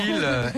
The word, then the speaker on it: Leelh: Lille, L-E-E-L-H.